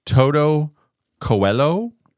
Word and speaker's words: Toto Coelho: It's by a group called, and I'm going to butcher the name. I probably should have researched how to say it, but it's Toto Coelho. – That's absolutely right.